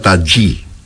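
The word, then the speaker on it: Tajy: Se escribe Tají con J, Y, pero me han dicho que se pronuncia Tají.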